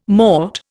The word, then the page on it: mort: Indian